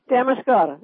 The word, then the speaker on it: Damariscotta: Damariscotta, Maine? – Yes. – Did I say that right? – Damariscotta, yes.